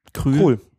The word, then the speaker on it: Krul: Krul. Nein, Krul. Der schreibt es ja nicht mit OE, dann wäre es auch Krul und so. Krul.